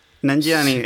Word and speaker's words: Nanjiani: Nanjiani, eso, sí, que lo he dicho bien desde el principio.